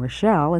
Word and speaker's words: Rochelle: My name is Rita, spelled R-I-T-A, and my last name, Rochelle, is spelled R-O-C-H-E-L-L-E.